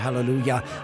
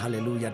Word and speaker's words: Halleluja: Und Halleluja, das war die englische Aussprache, Halleluja, die deutsche, und Alleluja, die italienische. Da sieht es jetzt auch nicht so schrecklich unterschiedlich.